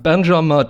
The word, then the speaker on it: Benjamin: Benjamin, danke.